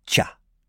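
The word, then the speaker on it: cha (茶): Et comme il s'y est diffusé par la voie terrestre, la prononciation en mandarin est cha.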